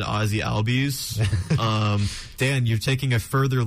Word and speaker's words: Albies: And I got Ozzie Albies. Is that how you say it? – Yeah, Ozzie Albies.